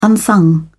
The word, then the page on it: unsung: British English